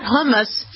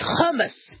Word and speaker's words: hummus: That's how you pronounce it, by the way. It's not hummus. It's hummus.